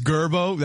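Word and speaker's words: Girbaud: Mabby says Gerbo. That's the proper pronunciation for the jeans.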